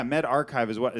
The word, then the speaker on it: medRxiv: Yeah, MedArchive is how they want it pronounced, I believe.